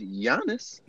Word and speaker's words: Giannis: I mean, the man tell you on the Metro PCS commercial is pronounced Giannis.